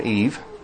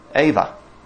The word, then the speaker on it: Eve: So, in Old English, people did not pronounce the name as Eve. We say Eve because we look at words and we usually pronounce them how they look to us. But in Old English, if you go back, they probably said something more like Ava. is how it would be pronounced, which is very similar to Chava.